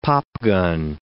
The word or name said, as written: popgun